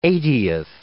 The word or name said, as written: eightieth